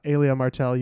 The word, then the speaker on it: Elia Martell: The pronunciation is said several times in the scene between the mountain and the viper as he exclaims to the mountain, Elia Martell, you will say her name.